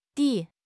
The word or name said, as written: di3